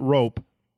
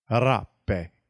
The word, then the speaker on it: Rappe: It's not rope. Rappe.